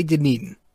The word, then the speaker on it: Dunedin: I did not say Dunedin. I did not say Dunedin. I'm not going to embarrass myself and say what I said, but I, I, I, certainly did not say Dunedin.